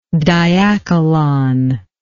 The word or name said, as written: diachylon